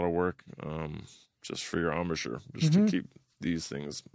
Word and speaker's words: embouchure: Yeah, how's that armature feeling all these years later? – Embouchure. – I always say it wrong.